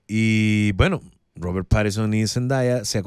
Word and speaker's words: Zendaya: Nunca nadie pronuncia bien mi nombre. Realmente es Zendaya, Zendaya.